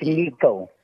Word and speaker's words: Nique: How is this pronounced? N-I-Q-U-E? – It's Nico.